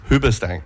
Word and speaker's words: Hoobastank: We got some music coming up in just a second, starting with The Reason by Hoobastank. Is that how you pronounce that? – Yeah. – Hoobastank.